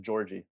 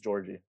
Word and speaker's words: Jaurji: It's super simple. It's just Georgie. – Georgie? – Yeah. – That's easier than the other ways we're discussing. – Everyone's like Georgie, because it's spelled weird. J-A-U-R-J-I. It's literally just Georgie.